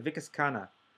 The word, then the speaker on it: Vikas Khanna: Vikas Khanna.